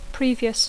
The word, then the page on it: previous: English